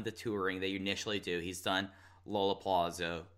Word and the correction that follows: Lollapalooza: I mispronounced that so bad. – That was fascinating. I've never heard anybody say it like that. – Lollapalooza. Lollapalooza. – Hold on. Say Lola. Wow. – Lollapalooza. There we go.